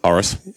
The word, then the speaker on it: Horace: The expression comes from an ancient Roman poet, Horace. Yeah. I'm saying that right. – Horace. – Yep.